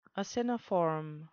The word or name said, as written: aciniform